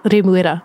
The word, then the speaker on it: Remuera: So I have lived in Remuera for a couple years, and a lot of people do not know that it's called Remuera.